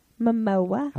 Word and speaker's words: Momoa: Momoa. How about that picture? – Mimosa. – It rhymes with Samoa.